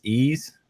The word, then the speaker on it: Ys: Uh, I think we agreed we pronounce this E's or, you know, YS.